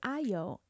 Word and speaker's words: Ayo: For example, my name, part of my first name, is Ayo, and that is spelled A-Y-O.